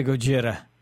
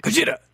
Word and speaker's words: Gojira: I like the Gojira. – You didn't say it right. – Gojira.